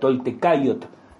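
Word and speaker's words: toltecáyotl: la toltequidad o la toltecayot, ¿no? como habría que pronunciarlo correctamente.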